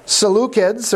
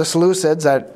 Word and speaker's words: Seleucids: And from here on out, the Seleucids, or the Seleucids, I've heard it pronounced both ways, became the kings of the north.